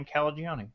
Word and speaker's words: Calagione: But craft beer icon Sam Calagione, and we discovered we can just think Chuck Mangione to get his name right.